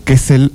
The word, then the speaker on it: Kessel: No, faltó una letra. – ¿Cómo es? A ver. – Kessel, así nomás.